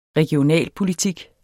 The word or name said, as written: regionalpolitik